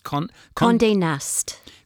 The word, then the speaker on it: Condé Nast: Condé Nast.